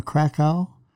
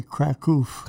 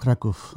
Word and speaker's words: Kraków: That was in Krakow, in the southern Poland. The Poles would say Kraków. – Kraków. – That's a pronunciation for them.